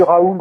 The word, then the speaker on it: Raoult: Et j'ai entendu Raoul, j'ai entendu Raoul ou Raoult, comme vous voulez le prononcer,